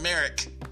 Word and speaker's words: Merrick: I think that because Merrick Garland, I keep calling him Merritt, because, you know, like black people say Jimmy Kimmel, like, I just can't get the name right. – Ambulance. – I say Merritt. Ambulance. I can't get it out. Merrick Garland, okay? I keep saying Merritt. Merritt Garland. Merritt. No, it's Merrick. So, Merrick...